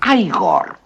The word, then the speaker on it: Igor: Usted debe de ser Igor. No, se pronuncia Igor. – Igor. – A mí me dijeron que era Igor. Pues estaban equivocados, ¿sabe?